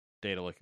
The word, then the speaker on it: Daedalic: You know how to pronounce that word. That is the same word that all the gods in Elder Scrolls are. – Oh, it's like Daedric. – Yeah. – So Daedalic is correct.